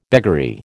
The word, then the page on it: beggary: American English